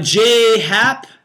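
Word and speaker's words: J. A. Happ: Jay Happ, that's the correct pronunciation of his name, even though it's J.A. He goes by Jay Happ.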